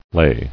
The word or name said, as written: Ley